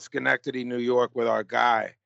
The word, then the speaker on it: Schenectady: Schenectady, yeah.